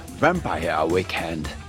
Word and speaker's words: Vampire Weekend: Mais oui, week-end vampire, bon sang, si je puis dire, mais c'est bien sûr Vampire Weekend, ou plutôt, comme dit Charline avec cette prononciation anglaise que lui envient tous les hindous du 10e arrondissement de Paris, Vampire Weekend.